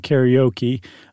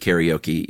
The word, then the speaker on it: karaoke: We'll pronounce it karaoke, even though it's karaoke.